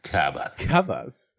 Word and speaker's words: Kabas: Kabas. So that's how you should pronounce it, by the way.